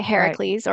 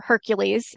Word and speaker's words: Heracles: He's injured by an arrow that's shot by Heracles or Hercules, as his name is often pronounced.